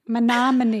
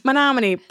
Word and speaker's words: Menominee: Menominee. – Menominee.